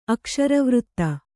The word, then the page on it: ಅಕ್ಷರವೃತ್ತ: akṣaravřtta